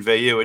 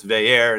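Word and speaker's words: Veilleux: But the one note we got is it's not Christian Veyu, it's Veyer, and that's like the third or fourth time we've had an about face on how to pronounce this quarterback's name.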